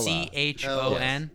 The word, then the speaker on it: Chon: C H O N. – Yes. Chon. – Yeah, I'm kidding.